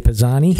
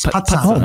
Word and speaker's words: Pisani: Oh, you know, with a double Z, you got to put the T in there, like pizza. It's Pizzani.